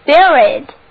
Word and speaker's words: spirit: Spirit， S-P-I-R-I-T， Spirit，注意这里辅音在 s 后面发音浊化了，读作 b，那么单词就读作 spirit。